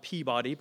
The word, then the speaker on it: Peabody: And my day started in Peabody, Massachusetts, and it's spelled Peabody, but we call it Peabody, uh, which some people think is puberty, which is a whole other process I'm still dealing with.